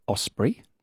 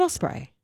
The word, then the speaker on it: osprey: I think it's osprey. – Is it really? – It might be. – Not osprey. Osprey. – It looks like Osprey, doesn't it? – I have been mispronouncing that my entire life.